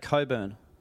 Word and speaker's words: Cockburn: it's out at Coburn, which is spelt Cockburn, so it's very funny.